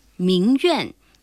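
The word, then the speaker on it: 名媛: 带女字旁的圆还有一个读音苑，名苑，指有名的美女，也指名门闺秀。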